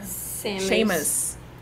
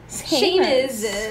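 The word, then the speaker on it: Samus: a próxima eu acho muito interessante falar, eu não conheço, mas eu achei, eu pesquisei um pouco sobre ela, que é Samu Semio. Como é que fala? É. – Seamus. – Seamus.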